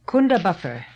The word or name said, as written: Kundabuffer